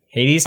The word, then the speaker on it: Hades: Hades? – Hades.